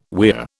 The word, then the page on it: weir: British English